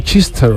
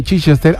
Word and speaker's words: Chichester: Eso sería, se pronunciará Chichester o algo así, ¿no? – Chichester, claro.